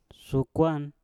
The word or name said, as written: sukwan